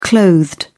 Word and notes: clothed: British English

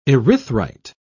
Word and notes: erythrite: British English

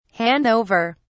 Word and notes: hand over: British English

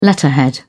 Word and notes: letterhead: British English

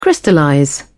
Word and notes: crystallize: British English

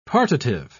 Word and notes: partitive: British English

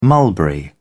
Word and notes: mulberry: British English

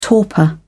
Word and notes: torpor: British English

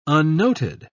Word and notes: unnoted: British English

